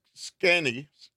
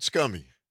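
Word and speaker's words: Sconnie: Scanny, like Wisconsin. Yeah, okay, Scanny Summer Giveaway. Download the Civic Media app and stay tuned for the keyword. How you pronounce that again? – Scanny. – All right, Scanny. Not scummy.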